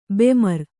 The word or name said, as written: ಬೆಮರ್